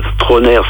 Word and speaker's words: Trauner: Troner, ça s'écrit T-R-A-U-N-E-R.